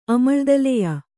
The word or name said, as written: ಅಮಳ್ದಲೆಯ